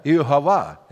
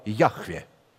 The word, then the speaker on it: Яхве: Имя Иегова – это неправильное произношение от слова «Яхве».